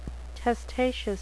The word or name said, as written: testaceous